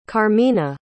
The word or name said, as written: carmina